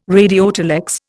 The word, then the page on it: radiotelex: Indian